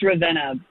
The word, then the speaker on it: Ravenna: On to, I believe it's Ravenna, Ohio. Am I saying that right? – It's Ravenna, but that's Ravenna.